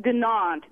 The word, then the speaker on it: Dunant: How do you pronounce Sarah? Is it Dunant? – I pronounce it Dunant. – Dunant. – D-U-N-A-N-T. That okay.